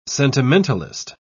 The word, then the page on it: sentimentalist: British English